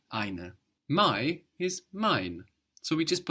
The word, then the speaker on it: mein: Mein. – Mein. – Mein. So mein means my. So ein is spelled E-I-N. We know this E-I gives us the I sound. And mine is spelled M-E-I-N.